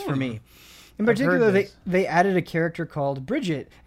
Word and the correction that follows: Brigitte: a support tank. – Brigitte. – Brigitte, yes, thank you. – Is that how you pronounce it? Brigitte? – Yeah, it is.